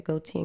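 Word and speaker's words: Gotye: Goatee, Kimbra y Kimbra, right? – Yeah, Goatee and Kimbra.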